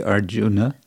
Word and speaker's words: Arjuna: Well, it depends if you want to be more like Sanskrit pronunciation, which would be Arjuna, or you want to be more like modern Hindi, which would be Arjun.